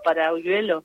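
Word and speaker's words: Paralluelo: Sí, Y tiene una, una jugadora que hay que prestar atención, se llama Salma para Paraluelio, sería bien pronunciado para Uyuelo, sí.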